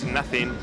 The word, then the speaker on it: NTHNG: Pues para un artista bastante interesante como es Nothing, aunque se escribe N-T-H-N-G,